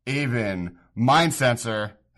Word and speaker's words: Aven Mindcensor: and that is Aven, Mind Sensor.